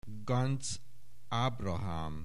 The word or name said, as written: Ganz, Ábrahám